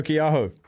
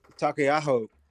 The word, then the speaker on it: Taukei'aho: Like, I think, um, you know, the hooking position has been fiercely competitive, like Codie Taylor and um, how do you say that name? – Samisoni um, Taukei'aho. – Taukei'aho, Mm.